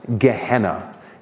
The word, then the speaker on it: Gehenna: Because there is another word that Jesus likes to use to refer to what we translate as hell, and it is pronounced Gehenna. Give me a good Gehenna.